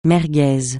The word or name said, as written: merguez